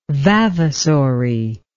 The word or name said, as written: vavasory